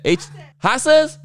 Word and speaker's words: haces: H-A-C-S. Wife, how do you say that? Haces? – Haces. – Haces. Like asses? Like fat asses? Okay, kinda.